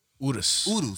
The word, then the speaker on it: Urus: Gunner's Lamborghini Urus. – That's how you pronounce it? – Urus.